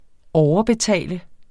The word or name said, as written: overbetale